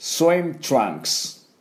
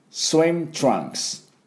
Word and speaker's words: swim trunks: Trunks は T-R-U-N-K-S で TRU-TRU-TRU-WC です、ね、それからウの音はあ U の音はあですが、あ電車止まってるの、あ喉の奥、あ Trunks で最後ね、クスクス WC K と S が両方入ってます。クスクスクス Trunks この音になります。では私の音について発音いきましょう。 Swim Trunks Swim Trunks。